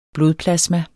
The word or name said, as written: blodplasma